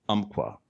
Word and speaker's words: Umpqua: And if she's not welding away in the shop, then she's outside either gardening or exploring the great North Umpqua.